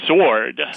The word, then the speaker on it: sword: Rods, R-O-D-S, plus W. – Rods plus W. Sword. – Sword. Nice job. You know, you see the word words there, but the W is pronounced in words. Only sword is the answer. Tile, T-I-L-E plus S.